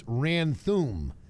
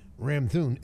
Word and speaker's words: Ramthun: It's actually brilliant marketing, because you get the Ram concept, and because most people think it's Ranthum with the N first and the M at the end. It's the other way around. It's Ram, R-A-M-T-H-U-N, Ranthum.